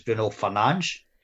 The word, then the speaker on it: Bruno Fernandes: So we all call Bruno Fernandes Bruno Fernandes, but supposedly in FIFA we've all got it wrong because it's Bruno Fernandes. – That's right, yes.